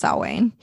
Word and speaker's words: Samhain: Yeah, I'll double check because I don't want to like incorrectly correct you, but I do think pronounced Samhain. – Karen in Georgia, my favorite murder. Spell it like you say it. – I know, right? Yeah, it's all good. – It's pronounced Samhain.